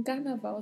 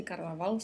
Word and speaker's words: Carnaval: De, que é preposição de, Carnaval, que a escrita é igual ao português, mas a pronúncia é diferente. A gente tem um R com vibrato, um V com leve som de B e a letra L ao final que é pronunciada com o som gerado tocando a ponta da língua na parte superior da boca.